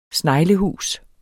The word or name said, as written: sneglehus